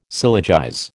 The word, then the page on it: syllogize: American English